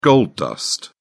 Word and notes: gold dust: British English

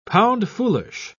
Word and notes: pound-foolish: British English

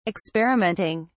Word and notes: experimenting: British English

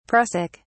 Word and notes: prussic: British English

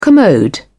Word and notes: commode: British English

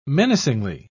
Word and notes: menacingly: British English

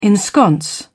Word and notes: ensconce: British English

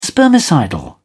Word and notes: spermicidal: British English

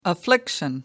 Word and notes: affliction: American English

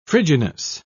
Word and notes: frigidness: British English

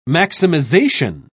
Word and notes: maximisation: British English

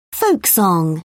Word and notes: folk song: British English

